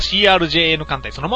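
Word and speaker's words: CRJN艦隊: 続きましては、トゥモルシークさん、代表の、 CRJN 艦隊。こちらは、読み方は CRJN 艦隊そのまんまだそうですね。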